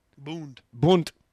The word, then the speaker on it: Bund: Nazi organization, the German-American Bund. – Bund. – Yeah, thank you.